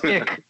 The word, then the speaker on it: iQ: The Scion IQ, which is pronounced ick, by the way.